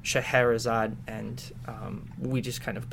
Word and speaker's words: Shahrazad: So what other sort of plans do you guys have at Shahrazad? Did I say that right? – Shahrazad, yeah. I mean, there's Shahrazad.